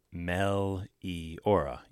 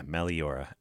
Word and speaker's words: Meliora: I'm not sure how you pronounce the name of it. I always have trouble. Meliora, I guess is the name of the record, right? Meliora. Yeah, Meliora. M-E-L-I-O-R-A.